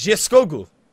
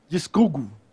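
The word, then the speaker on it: Geirskögul: I had someone tell me how to pronounce it. It's Gearskogle. – Gearskogle.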